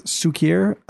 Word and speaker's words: Suiker: So that's I believe I'm saying this right. It's Sukier Apparel. Mm-hmm. I guess it means sugar Sukier. and South African. So S U I K E R Apparel.